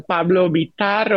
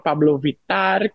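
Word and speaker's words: Pablo Vittar: No sé si pronuncié bien el nombre, si es Vitar o cómo se pronuncia realmente. – A las personas cuando hablan español hablan Pablo Vitar o Pablo Vitar, como quieran.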